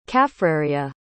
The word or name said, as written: kaffraria